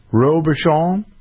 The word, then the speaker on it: Robuchon: Would you pronounce it that way in French? Robuchon? – Yep.